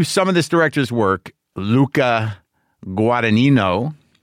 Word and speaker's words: Luca Guadagnino: Luca Guadagnino. – I did it. It's Brendan. Brendan's sending me the pronunciation. Luca Guadagnino.